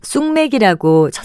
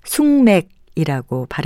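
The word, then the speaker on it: 숙맥: 이것을 쑥맥이라고 첫음자를 된소리로 발음할 때가 많은데요. 이 말은 콩숙자와 보리맥자로 이루어진 것으로 숭맥이라고 발음하는 것이 맞습니다.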